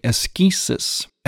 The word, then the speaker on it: askesis: Now, it's spelled A-S-C-E-T-I-S-I-S-M, asceticism, or ascetical, or the ascetics. Now, to use a little bit more of a Greek pronunciation, the word would be askesis, askesis.